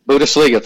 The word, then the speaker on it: Bundesliga: Bundesliga.